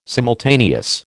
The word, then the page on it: simultaneous: American English